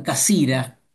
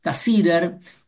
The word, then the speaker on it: Cassirer: Los alemanes lo pronuncian Cassira, casi, pero todos lo conocemos como Ernst Cassirer, uno quizás de los filósofos judíos más importantes del siglo XX.